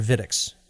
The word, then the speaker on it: Wittix: But you're also doing a fair bit of consulting work through your company Wittix, or Vittix. That's a W in front, but I'm not sure how it's pronounced. – So it's Wittix, and yeah, that's absolutely true.